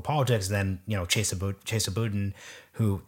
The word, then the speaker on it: Boudin: It's Boudin. – Boudin. – I say Boudin, but I only ever read it.